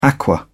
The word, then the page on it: aqua: British English